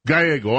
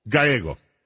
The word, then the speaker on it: Gallego: This business, Ruben Gallego. They said, I'm sorry? Gallego, I'm sorry. Mispronunciation, I got the ethnic thing wrong. Gallego. I hope I did it right that time.